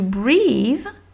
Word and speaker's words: breathe: Et donc, to breathe, to breathe, dans la prononciation, moi, avec un élève, je lui dirais d'écrire B-R-I, avec, parce que c'est le I français, bri the, the, donc ça peut être un, le TH, et tu sais que tu c'est une prononciation au mieux de ce que tu peux, tu peux faire du son TH, breathe, et ça s'arrête là.